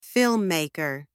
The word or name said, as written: filmmaker